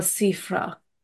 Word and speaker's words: Siffre: Michel Siffre, not Sifra. – Siffre. – Oh. – My American's coming out.